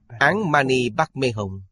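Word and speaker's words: Án Mani Bắc Mê Hồng (ཨོཾ་མ་ཎི་པདྨེ་ཧཱུྃ): À, phát âm chính là Án Mani Bắc Mê Hồng. Đây là cách phát âm của Tây Tạng.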